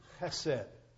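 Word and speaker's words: chesed (חסד): Instead, there was a word in the Hebrew that's pronounced chesed. You have to almost kind of spit when you say it, you know. You have to have a sinus condition like you grew up in Louisville, Kentucky. Chesed.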